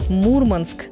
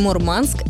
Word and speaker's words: Мурманск: Запомните, правильно говорить «Мурманск», а вариант «Мурманск» — это профессиональный жаргон, где язык подстраивается под ритм своей стихии.